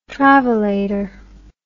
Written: travellator

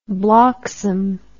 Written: bloxham

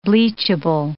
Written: bleachable

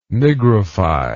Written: nigrify

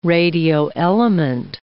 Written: radioelement

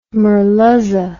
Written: merluza

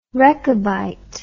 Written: rechabite

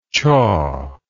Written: chawer

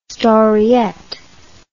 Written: storiette

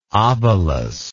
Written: obolus

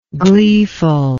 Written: gleefulness

